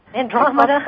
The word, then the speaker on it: Andromeda: Andromeda.